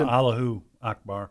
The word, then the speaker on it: Allahu Akbar: That's why they scream Allah Akbar before they. Is that how you pronounce it? – Allahu Akbar.